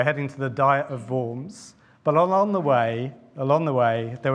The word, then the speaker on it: Worms: My German student son has confirmed to me that it should be Worms. So I'll try and stick with that. But if I slip into Worms occasionally, you'll kind of understand where we're going.